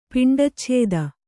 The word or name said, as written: ಪಿಂಡಚ್ಛೇದ